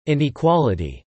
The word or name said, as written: inequality